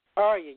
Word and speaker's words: Ariya: Ariya, yes, Ariya.